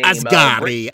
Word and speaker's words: Asghari: Asghari.